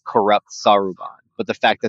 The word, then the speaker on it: Saruman: Saruman.